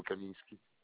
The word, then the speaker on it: Kaminski: c'est un Y dans le Kaminski.